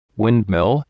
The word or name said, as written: windmill